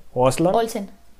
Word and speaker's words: Olsen: Recuerdo mucho que había memes de esta Elizabeth Oswen, si lo, si lo dije bien su apellido. Olsen. Olsen. Elizabeth Olsen.